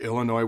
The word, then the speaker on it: Illinois: Again, all due respect to Illinois, where the S is silent.